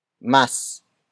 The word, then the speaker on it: mas: Mas murang sabon. The first word is mas. Mas. Mas. Mas is spelled as M-A-S.